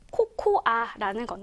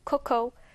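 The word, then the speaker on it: cocoa: 우리가 흔히 알고 있는 코코아라는 건데, 영어 발음은요, 코코, 코코.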